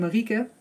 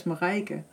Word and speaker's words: Marieke: And for my name, Marieke, is pronounced in English very difficult. And it usually comes out as Marijke.